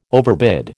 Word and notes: overbid: American English

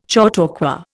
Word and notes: chautauqua: Indian

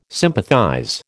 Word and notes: sympathize: American English

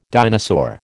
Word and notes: dinosaur: American English

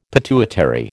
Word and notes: pituitary: American English

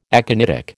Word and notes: aconitic: American English

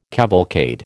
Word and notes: cavalcade: American English

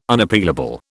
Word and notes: Unappealable: British English

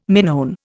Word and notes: Minoan: Indian

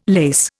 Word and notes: lace: Indian

